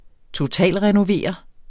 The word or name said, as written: totalrenovere